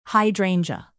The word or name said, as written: hydrangea